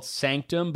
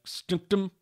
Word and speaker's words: SNCTM: Another one, in a club in Los Angeles and New York City called, it's really weird, it's spelled S-N-C-T-M. So I'm assuming it's called Sanctum, but it would be like Snooktum. Sanctum, I guess, is what it is, but I'd like to buy a vowel.